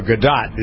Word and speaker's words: Gadot: All right, speaking of Wonder Woman, Gal Gadot, or Gadot is correctly pronounced, I would have done Gadot thinking it's French, but it's a Jewish name, is taking a stance against sexual predators.